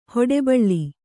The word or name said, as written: ಹೊಡಬಾಳು